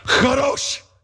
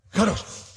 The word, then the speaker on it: Kadosh: If the person in front of you is not wiping stuff off the back of their hair, you didn't say it right. Kadosh. Kadosh.